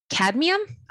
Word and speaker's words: cadmium: Cadmium? – I was just going to say cadmium.